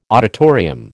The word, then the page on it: auditorium: American English